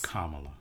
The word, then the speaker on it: Kamala: Kamala. – Yes. Oh, you know what? I am saying it wrong. Kamala.